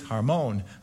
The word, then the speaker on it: Hermon: I actually was trying to figure out where the syllable goes. Is it Hermon or is it Hermon? And so I looked up, how do you pronounce this mountain? And it's Hermon. So the syllable's on the second.